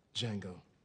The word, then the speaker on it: Django: Django. – Can you spell it? – D-J-A-N-G-O. The D is silent.